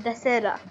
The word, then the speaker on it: Dasara: All of us pronounce it a different way, but it's called Dasara. Yeah, some guys call it Dashera.